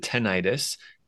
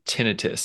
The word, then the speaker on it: tinnitus: Yeah, most of the world pronounces it tinnitus. However, most doctors in the audiology, ear, nose, and throat field pronounce it tinnitus. So many patients come to us and they say, yeah, I have this tinnitus, and sometimes they hear tinnitus. Either can work,